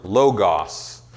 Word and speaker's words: logos: The New Testament word for word is the Greek word logos.